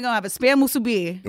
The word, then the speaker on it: musubi: Oh, you said a little different. Yeah. I was like, I learned something. – Musubi. – Musubi. – Musubi. – Emphasis on the mus.